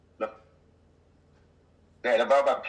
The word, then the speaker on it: Le Baobab: You've just mentioned, so you run Le Baobab? Is that how it's pronounced? – Le Baobab cuisine. Le Baobab is a big tree.